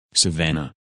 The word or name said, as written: Savannah